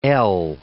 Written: ell

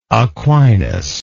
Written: Aquinist

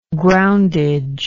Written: groundage

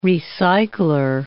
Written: recycler

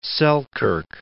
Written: Selkirk

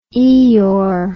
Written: Eeyore

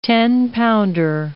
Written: tenpounder

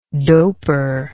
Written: doper

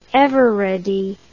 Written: eveready

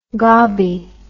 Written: gobbi

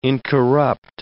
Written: incorrupt

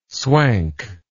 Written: swank

